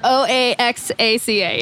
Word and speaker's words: Oaxaca: Yeah, is how you say Oaxaca. Oaxaca, yeah.